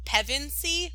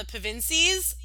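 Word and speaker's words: Pevensie: It was just mind-blowing to me, which, speaking of the word Pevensey, which is how you say their last name, mm-hmm. It annoyed me because he called them the Pevensies, and I was like, no, don't you dare.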